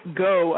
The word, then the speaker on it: Gough: Michael Gough, I believe is how it's pronounced, G-O-U-G-H,